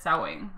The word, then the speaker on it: Samhain: They totally said it wrong, but it's Samhain. That's how I say it.